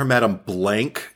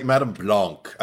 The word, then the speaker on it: Madame Blanc: I don't even like calling her Madame Blank, even though everybody in the movie like pronounces it that way. It feels so ugly and American. Yeah. – That's why I say Madame Stank. – I don't imagine her name is ugly and American. I just imagine that like Madame Blanc. I like, and that's how they pronounced it in the remake.